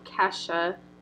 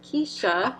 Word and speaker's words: Kesha: Anyway, so my second embarrassing moment for you is when you pronounced Kesha, the very popular pop singer, as Keisha.